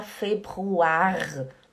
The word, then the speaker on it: Februar: O Februar esse R do final a gente não pronuncia, a gente, a gente não fala februar, né? É como se não existisse esse R no final. Você fala februar, februar, né? Februar. O R é aqui na garganta.